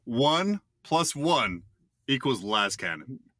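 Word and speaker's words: lascannon: Should it be pronounced correctly, like Laz Cannon, or moronically like this? – Sorry, Tyler, but that guy. Like, seriously, what a bad opinion. Like, come on. Everybody knows it's Laz Cannon.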